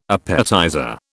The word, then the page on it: appetizer: British English